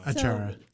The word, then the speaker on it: achara: Achara? No, I said it wrong. – Achara.